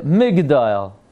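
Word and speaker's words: Migdol (מגדול): Now the truth is it's spelled Magdil, Mem Gimel Dalad Yud Lamed. And it's read Migdol. Migdol.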